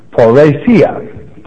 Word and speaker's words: parrhesia: So when Jesus used the word open here in the Greek, it is parousia. Parousia. It is spelled P-A-R-R-H-E-S-I-A. And again, pronounced parousia.